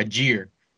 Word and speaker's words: Allgeier: the rookie Tyler Allgeier, and yes, that's how you say it, motherfuckers.